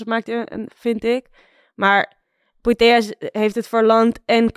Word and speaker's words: Putellas: Is Alexia Putellas? – Putellas, ik moet de L niet uitspreken.